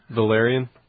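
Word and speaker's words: Velaryon: Is that how you say it? Valyrian? – Valyrian. – Yeah, but there's no I there. That's why I'm not saying Valyrian.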